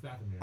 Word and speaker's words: Dathomir: Is the N silent? – Mm-hmm. – That makes no sense. – In the show, it's pronounced Dathomir.